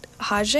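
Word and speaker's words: Rogê: I don't expect anyone to remember that, but they're called Hage. I think that's how you pronounce it. It's spelled R-O-G and then an E with the dash over it.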